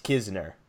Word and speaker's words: Knizner: the spelling of the last name is Knizner, spelled Knizner, but is pronounced Kisner.